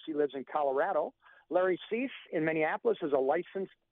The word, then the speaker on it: Suess: It looks like Seuss, S-U-E-S-S, but it's pronounced Cease, like cease and desist.